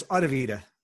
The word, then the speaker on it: AudioVita: Am I pronouncing that correctly? – Not quite. It's AudioVita. – AudioVita. – Easier than what you said. Yes. One less syllable anyway.